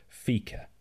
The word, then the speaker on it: fika: Fika, F-I-K-E, or Fika, okay. Fika.